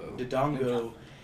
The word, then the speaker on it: Dodongo: And I'll never forget, like, the first time I, like, got stuck was on Dodongo, or Dodongo, I can't ever pronounce his name. – Dodongo.